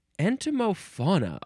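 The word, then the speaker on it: entomofauna: Next is entomophona. Entomophona. Let me just make sure I got the pronunciation right. I think I do. Yeah. Entomophona. Okay. Well, it's the prefix entomo. Yeah. E-N-T-O-M-O. And then the word fauna. F-A-U-N-A.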